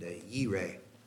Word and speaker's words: Jireh: J-I-R-E-H. – Okay, the Yahweh at the beginning like we had earlier, and then J-I-R-E-H. So then J is pronounced with a Y sound, as in Yubilate and Yireh.